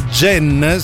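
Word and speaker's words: Genn: Allora, vi dico subito che Jen si scrive G, G come Giuliano, E come Enzo, N come Napoli, N come Nocciolino.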